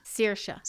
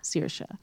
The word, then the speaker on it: Saoirse: It's Sorsha or Saoirse? Saoirse. Saoirse.